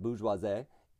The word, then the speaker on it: bourgeoisie: Now, this is a French word, that's why I'm pronouncing it bourgeoisie. If you do ask the dictionary, i.e., Google, it's a little less, um, how shall we say, uh, refined? – Bourgeoisie.